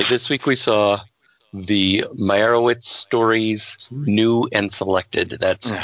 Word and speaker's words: Meyerowitz: It's Meyerowitz. There's an O in there. – That's what I said. Meyerowitz.